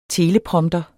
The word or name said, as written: teleprompter